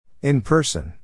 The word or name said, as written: in person